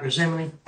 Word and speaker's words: Gethsemane: And the only time that you see him kind of a little shaken is in the Mountain of Gethsemane. – Mm-hmm. – Is that how you say it? – Oh, yeah, Mount Gethsemane.